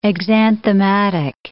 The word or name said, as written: exanthematic